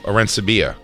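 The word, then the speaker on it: Arencibia: Do you think I said his name right? – Dan. – Arensiba? – No. – No? I'm terrible at this kind of shit. – You may be right. No. Arensibia.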